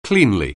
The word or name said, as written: cleanly